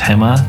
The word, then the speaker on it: Haema: And for her restaurant, her upcoming restaurant, it's Hema, that's spelled Haema.bk, if you wanted to follow along her journey.